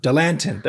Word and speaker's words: Dilantin: Dr. Luthi's records actually confirm that her parents obtained a renewed prescription for Dilatin, I think is how you say it. Dilatin, whatever she was taking before. She's still on that. Okay, Dilantin. There we go.